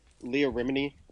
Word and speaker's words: Leah Remini: And I want to say Leah Remini. Am I saying that correctly? – Yep.